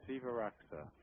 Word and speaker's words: Sivaraksa: Is that the correct pronunciation, S-I-V-A-R-A-K-S-A, or is it Sivaraska? – No, Sivaraska.